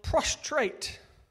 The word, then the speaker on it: prostrate: Now, I've got to pronounce this right and not slip up, as I did this morning, is that they are prostrate. Yeah? Prostrate.